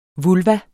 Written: vulva

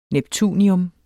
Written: NEPTUNIUM

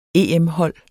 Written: EM-hold

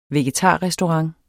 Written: vegetarrestaurant

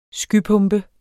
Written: skypumpe

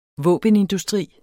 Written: våbenindustri

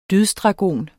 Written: dydsdragon

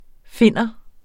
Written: finne

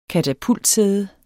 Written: katapultsæde

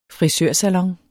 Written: frisørsalon

